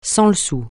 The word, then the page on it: sans-le-sou: francais